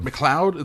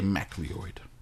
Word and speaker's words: McLeod: the last name M C L E O D. Like Mc McLeod. McLeod. – It's McLeod. – It's pronounced McLeod. – I was going to say MacLeod. Mac, Mac, McLeod. McLeod.